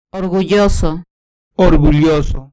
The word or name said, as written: orgulloso